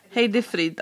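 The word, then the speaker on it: Heidi Fried: Och jag tänkte på, eh, hur uttalas hennes namn? Heddy Fred? Heidi Frid, kanske. Heidi Frid, ja.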